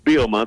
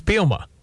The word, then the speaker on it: Bielema: The play-by-play guy in Wisconsin apparently says it's three syllables, but here's what he said to us when he was hired. New head football coach at the University of Illinois. He's done a million of these, and I'm just million number one. Ladies and gentlemen, Brett, is it Bielema? That's the proper way to say it, isn't it, Brett? – It's pretty close. It's Bielema the second he's a little silent, though. – Okay. It's Bielema. – Yeah.